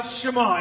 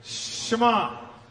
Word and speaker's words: Shema: This is called the Shema. Everybody say it together. Shema. It's really easy. Shema. Say it with me. Shema.